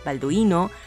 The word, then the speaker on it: Balduino: a Balduino, Balduino, Balduino, con acento en la I, Balduino, a Meneo.